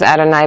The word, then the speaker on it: Adonai: And that Adonai is specifically the one that is spelled with the Yud and the Hey and the Vav and the Hey, which we don't pronounce. So we pronounce it as Adonai, but actually, in fact, we do sometimes have the word Adonai also in our prayers, and that's not necessarily mercy.